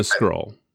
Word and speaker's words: Skrull: Is it the Krill or the Krull? – The Krill. – Who are the aliens? Are they Krill? – There's the Krill and the Skrull.